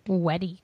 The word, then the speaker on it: UEDI: It's pronounced Weddy. – What? Oh, Weddy. Okay. So they're going Japanese pronunciation or something. All right. What is this? Weddy. Oh, Weddy. Like W. Weddy.